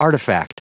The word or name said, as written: artifact